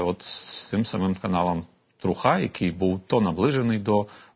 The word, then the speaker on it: Труха: Щодо свободи слова, я тут не буду вже казати про кейс Еспресо, але скажу про інший кейс, це так званий телеграм-канал Труха, чи Труха, як вони наполягають з наголосом.